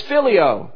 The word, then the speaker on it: philia: The second type of love we see in the Bible is philia. Now that sounds like the word Philadelphia.